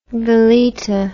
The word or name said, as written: veleta